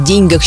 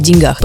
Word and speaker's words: деньгах: Ну, например, наше любимое выражение «не в деньгах счастье». Только в единственном в этом выражении ударение здесь ставится на «е». В остальных случаях мы бы сказали «в деньгах».